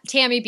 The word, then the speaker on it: Tammy: I'm also at Tammy Bjelland.